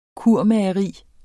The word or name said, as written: kurmageri